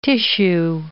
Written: tissue

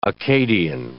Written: Akkadian

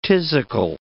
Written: phthisical

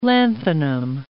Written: lanthanum

